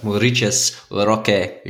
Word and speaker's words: Maurides: But Maurides, the Brazilian dude. I asked my Brazilian friend how to say it. So, Maurides Roque Jr. So, very sexy, huh? – Very good.